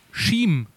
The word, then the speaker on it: Schim: Schiem, Chim äh, gehört, gesehen? – Ich glaube nicht. – Also geschrieben S-C-H-I-M. Ich glaube, Schiem spricht man es insofern auch. S-C-H-E-M. I-M. I wie Ivida.